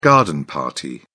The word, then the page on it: garden party: British English